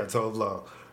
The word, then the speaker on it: Tove Lo: Well, so, Tove Lo's new record, Sunshine Kitty. – Is that how you pronounce it? – Yeah, Tove Lo.